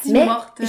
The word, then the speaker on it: Tim Hortons: Un Tim Hortons, c'est un Tim Hortons. Nous, là, le trait d'union, là, il est après le Tim. – OK. – C'est comme ça qu'on prononce ça. Tim Hortons.